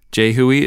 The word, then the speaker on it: JHUI: Jehui is spelled J-H-U-I, and it stands for the James Hill Ukulele Initiative.